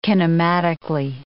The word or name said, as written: kinematically